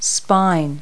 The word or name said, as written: spine